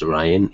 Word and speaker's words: Rian: Rian Johnson, I believe that would be pronounced Ryan, even though it's R-I-A-N.